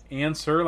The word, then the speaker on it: ANSER: Answer, like ANSWER? – Yes, but it's spelled funny. It's like ANSER.